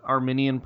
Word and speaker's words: Arminian: But in terms of understanding works and works of the law, it's not, from an Arminian perspective, a meritorious work that accomplishes anything.